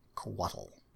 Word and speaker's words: Couatl: All right, Coatl. – Spell that, please. – C-O-U-A-T-L. Coatl.